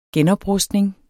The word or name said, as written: genoprustning